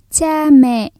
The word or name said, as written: cha mẹ